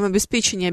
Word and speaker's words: обеспечение: А вот как э, вам обеспечение обеспечение? Нас в университете учили легко запоминать правильное ударение, а в обеспечении никакого печенья нет.